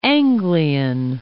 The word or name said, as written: Anglian